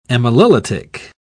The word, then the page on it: amylolytic: British English